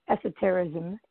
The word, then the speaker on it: esotericism: So all of the principles of esotericism, did I say that right? – Yes.